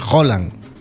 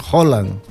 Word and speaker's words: Haaland: En Noruega las dos A juntas es O. Entonces Haaland.